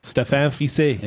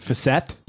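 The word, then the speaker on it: Fiset: Stéphane Fissé. – Yeah. – That's great. Nice. And also, I love French guys like that where people, some of the English announcers would pronounce it Fisset. – Yes.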